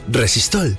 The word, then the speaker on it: Resistol: El resistol se llama así por el vocablo inglés resist all, que se pronuncia resistol.